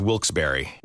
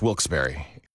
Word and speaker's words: Wilkes-Barre: Now, I had heard that it was supposed to be pronounced Wilkes-Barre. Wilkes-Barre Scranton's 97.9X. The only way to pronounce Wilkes-Barre.